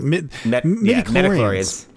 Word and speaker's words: midichlorians: Emperor Lucas introducing us to hydroxychlorians. I'm sorry, I said that wrong. Midichlorians.